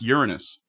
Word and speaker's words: Uranus: All right, I am Rob Bartholow, creator of My Cock Enters Uranus, and I believe it's pronounced Uranus. – Yeah, you're right. My cock is going to urine us.